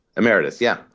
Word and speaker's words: emeritus: By the way, is that how you pronounce that? Emeritus? – Emeritus, yeah.